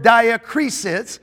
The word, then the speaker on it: diakrisis: Discernment comes from the Greek word diakrisis. It's spelled D-I-A-K-R-I-S-I-S.